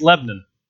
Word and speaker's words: Lebanon: There are 35 Franklins, 35 Lebanons, and if you're from Missouri, it's Lebanon.